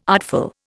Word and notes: artful: Indian

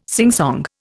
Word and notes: singsong: Indian

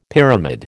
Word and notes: pyramid: American English

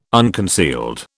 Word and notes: unconcealed: British English

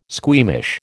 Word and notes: squeamish: American English

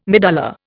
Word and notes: medulla: Indian

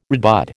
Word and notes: redbud: American English

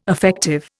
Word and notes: affective: Indian

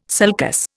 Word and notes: sulcus: Indian

